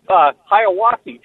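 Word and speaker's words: Hiawassee: Let's go now in Hissawasa, Georgia. Did I say the name correctly? – Nope. Hiawatha, Georgia.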